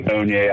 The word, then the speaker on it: Onye: Hi, this is Onye Ahnotu.